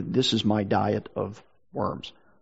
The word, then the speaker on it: Worms: When we read that, by the way, looks like, or it says worms, okay, W-O-R-M-S. But in German, it's pronounced Worms. It's the name of a city.